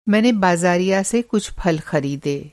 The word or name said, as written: बाज़ारिया